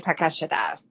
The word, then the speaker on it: Takeshita: On AEW, they say Takeshita.